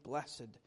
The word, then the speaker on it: blessed: And while we read this and other psalms, we'll see, as many psalms do, begin with the word, verse number one, the word blessed, or as we may pronounce it formally, blessed.